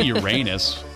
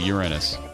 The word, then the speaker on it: Uranus: Well, you could say Uranus. Nothing wrong with that. That's how we said it in school, Uranus. But then they said it's actually Uranus. – Yeah. Uranus works. – Put stress on the first syllable for Uranus.